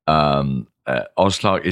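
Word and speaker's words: Oz Clarke: So I should call you Oz Clark. That's pronunciation. – Oz Clark is the way to pronounce it.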